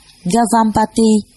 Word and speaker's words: Gavampati: Ngài Kiều Phạm Ba Đề, Ấn Độ đọc là Gavampati, chữ Hán là Ngưu Tư.